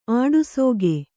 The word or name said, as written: ಆಡಸೋಗೆ